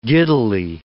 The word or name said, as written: giddily